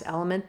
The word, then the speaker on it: LMNT: I like to use Element, or LMNT. I think it's pronounced Element though.